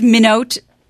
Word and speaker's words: Minot: No, it's just Minot, I think. It's Norsk Minot.